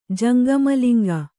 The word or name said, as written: ಜಂಗಮಲಿಂಗ